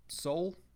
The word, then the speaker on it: Seoul: like main area through Seoul, right? Is that how it's pronounced? – Yeah.